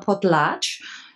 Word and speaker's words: Potlatch: Und der, wieder so ein lustiges Wort, das nennt sich Potlatch. Schreibt man auch so, ja. Also nicht Podcast, sondern Potlatch.